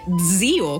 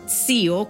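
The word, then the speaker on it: zio: Опять же, будучи итальянистом уже много тысяч лет, я когда это узнала, а я это узнала 4 года назад, на минуточку, что правильно говорить не «дзио», как на севере говорят, там же все умные, а «цио», как на юге,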